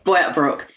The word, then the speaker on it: Boyup Brook: So tell me, why did you make the move to, is it Boyup Brook? Am I saying that correctly? – Yes, Boyup Brook.